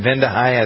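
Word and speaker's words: Vande Hei: Speaking of things that come out in the wash, NASA astronaut Mark Vendehai, is that how you pronounce the name? Vendehai? I think it is.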